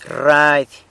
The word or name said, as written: rrady